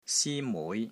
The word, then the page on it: SI1 MUI6: Cantonese